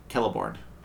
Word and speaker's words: Celeborn: I think they call him Celeborn, with a hard C.